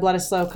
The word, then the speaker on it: Bledisloe: I actually got to go to the second test of the Bledisloe Cup, All Blacks versus Australia, as you know.